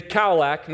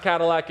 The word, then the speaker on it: Kaolack: But as we're driving away from Dakar, we're going through this city named Kaolack, not Cadillac. In English, it would be spelled like K-A-O-L-A-K, so Kaolack.